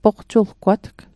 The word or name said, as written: poqtuggwatg